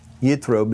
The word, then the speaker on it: Yitro: This week, the tidbit is taken from the portion that is called Yitro, which refers to Moses' father-in-law, Jethro, Yitro being the Hebrew pronunciation.